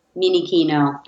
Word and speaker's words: Minichino: And my last name is Minichino for all of you who are scared to pronounce it because it's Italian and it looks like Minichino, which could be small khakis. I'm not small khakis.